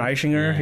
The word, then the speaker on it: Eichinger: Eichinger? – Bernd Eichinger, yeah.